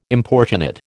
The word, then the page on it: importunate: American English